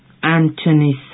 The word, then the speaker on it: Anthony: It is spelled A-N-T-H-O-N-Y. – Anthony. Listen. Anthony. The word contains the sequence of letters TH, but TH here is pronounced as T, simple T, not a F.